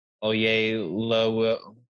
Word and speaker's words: Oyelowo: George Clooney, Kyle Chandler, Felicity Jones, David Oyelowo, that's how you say his name.